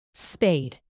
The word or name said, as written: spade